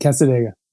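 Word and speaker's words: Cassadaga: Casadega, is that how you pronounce this? – Yep, Casadega.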